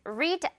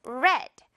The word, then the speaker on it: read: 여기서는 read 아니고 read.